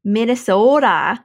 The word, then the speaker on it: Minnesota: So we're how would you say my state's name? – Minnesota. – Yeah, there, you nailed it. – That's how everybody in Missouri says it. – Minnesota. – Is that not how you say it? – Yeah, that's exactly how we say it.